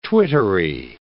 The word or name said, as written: twittery